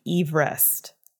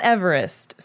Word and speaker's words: Everest: His name was Sir George Everest. Not Everest. So we've been pronouncing his name wrong this entire time.